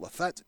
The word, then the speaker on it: Lafitte: By the way, folks, the Lafette is spelled L-A-F-I-T-T-E. And I have listened to that be pronunciated to me several times because French is not my native tongue, but I listened to it. Lafette is what I was able to pronounce out of that, so let's move on.